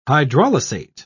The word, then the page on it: hydrolysate: British English